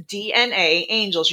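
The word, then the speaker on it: DNAngels: It is DNA angels. You pronounce both A's, but you only spell it with one.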